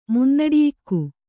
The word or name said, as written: ಮುನ್ನಡಿಯಿಕ್ಕು